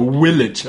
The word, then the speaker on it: Village: Oder Village, was ich auch so hasse, wenn Leute das sagen. It's a V, not a W.